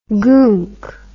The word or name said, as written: goonk